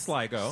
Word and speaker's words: Sligo: The Sligo. Correct? – Yes. I think it's Sligo.